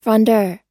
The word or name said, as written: frondeur